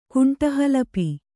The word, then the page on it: ಕುಂಟಹಲಪಿ: kuṇṭa halapi